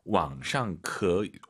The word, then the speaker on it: 网上: 우리가 보통 이제 한국어로 인터넷상에서라고 할 때 인터넷상 할 때 상이 샹이라고 보시면 될 것 같습니다. 왕샹크.